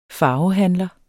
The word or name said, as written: farvehandler